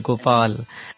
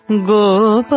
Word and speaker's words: Gopal: times when we sing gopal, the l we miss to clearly pronounce. Gopal, yes. – and the L is never heard.